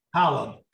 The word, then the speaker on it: Holub: Holub.